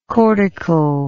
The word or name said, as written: cortically